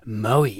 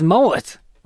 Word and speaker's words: Moët: They have a cellador in Victoria, and you do the tour, and they say it's pronounced Moët. Or something, like you actually do pronounce the T, or it's got some weird pronunciation.